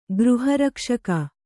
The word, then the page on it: ಗೃಹರಕ್ಷಕ: Kannada